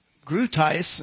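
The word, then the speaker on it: Groothuis: Grutius is this guy's name. He's a professor in philosophy. That's how that's pronounced.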